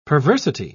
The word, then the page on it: perversity: British English